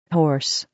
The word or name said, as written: horse